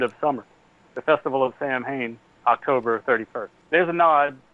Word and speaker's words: Samhain: A word that's spelled S-A-M-H-A-I-N. And somebody says, well, there's some kind of nonsense word on the board here. And then Dr. Loomis says, no, that's Samhain. It's a Celtic word.